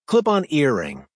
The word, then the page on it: clip on earring: American English